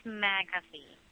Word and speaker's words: McAfee: It's McAfee.